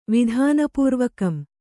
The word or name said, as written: ವಿಧಾನಪೂರ್ವಕಂ